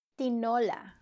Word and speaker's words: Tinola: Tinola.